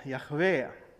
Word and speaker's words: Jahve: Det fanns inga vokaler i den gamla hebreiska, så vi vet inte exakt hur Guds namnet ska uttalas. Men det mest vanliga är att man säger Jahve.